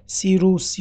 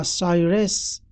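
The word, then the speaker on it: Cyrus: امپراتوری ایران در زمان کوروش که نام او را در غرب با قلب تلفظ حروف یونانی سیروس یا سایرس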